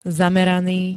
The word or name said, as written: zameraný